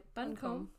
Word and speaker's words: Buncombe: How do you say it? – Like honeycomb. – Yeah, Buncombe.